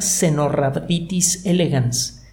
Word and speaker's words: Caenorhabditis elegans: Una especie de nematodos especialmente atractiva para los laboratorios de investigación se llama Cenorhabditis elegans. Se escribe Caenorhabditis elegans.